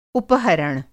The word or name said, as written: उपहरण